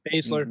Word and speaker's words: Baszler: Am I saying that right? Is that her name? – Yes. – Okay. Baszler? Baszler? – Baszler.